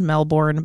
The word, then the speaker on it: Melbourne: And I know it's spelled Melbourne, but when I went there once for like a week, they said that the locals call it Melbourne.